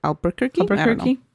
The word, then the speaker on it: Albuquerque: Albuquerque? – I guess. – Yeah, that's how you pronounce it.